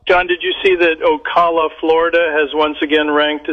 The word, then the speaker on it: Ocala: I think for the joke to work, you have to know it's pronounced Ocala.